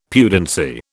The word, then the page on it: pudency: British English